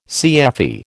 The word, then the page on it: CFE: American English